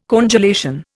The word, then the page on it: congelation: Indian